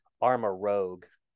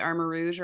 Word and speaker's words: Armor Rouge: I thought it was I'll be honest with you, since I'm not smart and you are, I was thinking it was like Armor Rogue. – How would you say it, Gracie? Armor Rouge or Armor Rogue? – I think it's Rouge now that you said it that way.